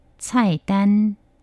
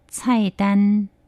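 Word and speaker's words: 菜单: 우선은 식단 또는 메뉴를 얘기할 때 차이단, 차이단, 차이단, 메뉴 주세요.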